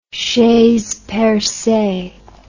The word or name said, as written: chaise percee